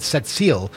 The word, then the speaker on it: Sept-Îles: I pronounce it Sept-Îles, but everybody in Quebec pronounces it Sept-Îles. And they swear they're not inserting another S in there, but they are. Sept-Îles. You hear the S, right?